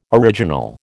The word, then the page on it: original: American English